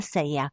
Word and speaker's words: сая: Последна на пътя на Галема се появила една сая. Тази дума се произнася с ударение върху я.